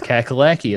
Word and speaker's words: Kakalaki: North Kakalaki, that's right. That's, see, he knows. – You got to pronounce that right. – He knows. – You can verify that it's Kakalaki.